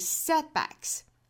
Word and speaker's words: setbacks: 所以把重读呢落在第一个音节上面是 setbacks. Do not allow setbacks. 那我们还要注意中间的这个 set 的 t 的发音呢，要把它给顿一下 setback 相当于是两个爆破辅音相遇在这个单词内部了，我们也是要把前面的这个失去爆破的，所以 setback 后面加复数 setbacks。